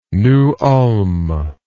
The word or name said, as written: New Ulm